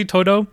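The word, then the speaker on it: Todo: Because when you first see Todu, I don't know if I'm pronouncing his name right. I think he. – Yeah, that's right.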